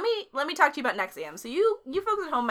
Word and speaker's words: NXIVM: Well, first of all, if you're going to Google it, here's how it's spelled. N-X. I-V-M. – That's confusing. – But it's pronounced Nexium.